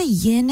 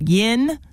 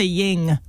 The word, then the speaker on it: yin: Also, thank you for saying it right. Yin, yang. A lot of people say ying. – I know. – I only learned that about 6 months ago. It's not ying. – No, it's yin and yin.